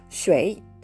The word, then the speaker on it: 水: シュエイ」というふうに言ってしまって全く問題ありませんはいちょっとね発音の、えー、解説が長くなりましたがとにかく「お湯」えー「左右」ですねという意味で「シュエイ